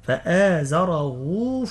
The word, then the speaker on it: فآزره: هنا مضمومة نقول فآزره فاستغلظ. كيف ننطقها؟ ننطقها هاء بعدها واو، هي مرسومة في المصحف هاء عليها ضمة، لكن في النطق هاء واو، ننطقها هاء واو، فآزره فاستغلظ.